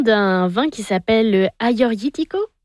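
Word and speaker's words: Agiorgitiko: Vous êtes très bonne, parce que pour ceux qui nous écoutent, le Aioritiko, en fait, c'est deux G, mais les G, on les prononce comme les Y.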